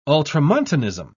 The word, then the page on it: ultramontanism: British English